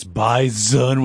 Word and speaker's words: bison: Also, if you can't listen to that clip without being like, it's bison with a Z, pronounce it like a Z, congratulations, you're appropriately Fargo-fied.